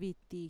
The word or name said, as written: viti